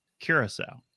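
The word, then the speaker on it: Curaçao: well, it's nice when I have the thing in front of me now that says pronounced curacao.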